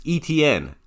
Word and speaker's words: Etienne: Second tier, Travis Etienne.